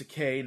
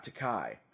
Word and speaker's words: Takei: Scott says, "George Takei is pronounced Takei, not Takai.